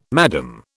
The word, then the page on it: Madame: British English